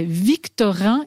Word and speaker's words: Victorin: Alors là, je vais le prononcer, vous allez me corriger si je prononce ça faux. C'est Victorin et Victorine. – Bien c'est juste.